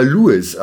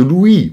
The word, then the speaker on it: Louis: Und zwar, er spricht sich nicht etwa Louis aus, in englisch-amerikanischer Art, sondern französisch, also Louis, nach irgendwelchen Ahnen aus dem Königshaus.